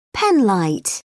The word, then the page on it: penlight: British English